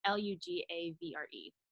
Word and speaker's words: Lugavere: Yeah, no, I don't like Gundry, but it's by a guy, I don't know how to pronounce his last name. It's Max Lugavere. It's Lugavere.